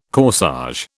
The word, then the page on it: corsage: British English